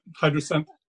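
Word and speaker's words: Hydrasynth: Hydra synth? – Yep, Hydra synth.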